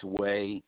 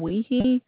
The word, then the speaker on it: Wehe: Last name is W-E-H-E, pronounced Way. And zip code is 98072. – Okay, I'm taking a look now. – Yep, thanks. – Tom, I have a Tom Weehee.